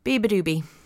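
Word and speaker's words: beabadoobee: Said it right today. There you are, Tom. See, I can do it. Be Bad B. Be Badoobie. Be Badoobie.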